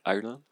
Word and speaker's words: Ireland: Justina Ireland Island. Comment tu l'as prononcé Ireland ah, Irlande.